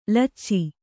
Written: ಲೊಚ

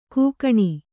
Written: ಕೂಗಣಿ

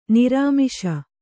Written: ನಿರಾಮಿಷ

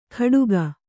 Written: ಕಡುಗ

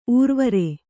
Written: ಊರ್ವರೆ

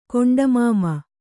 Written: ಕೊಂಡಮಾಮ

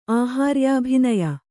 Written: ಆಹಾರ್ಯಾಭಿನಯ

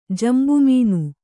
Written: ಜಂಬುಮೀನು

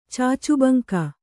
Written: ಚಾಚುಬಂಕ

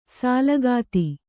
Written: ಸೂಲಗಿತಿ

